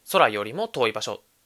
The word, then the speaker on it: 宇宙よりも遠い場所: 宇宙よりも遠い場所と書いて空よりも遠い場所と読むん